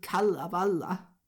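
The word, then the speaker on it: Kalevala: Og i stedet for etteren, så har de digtet Kallaballa. – Ja, det er godt, du udtaler sådan, det er jeg glad for. Det er vigtigt, at man udtaler det rigtigt.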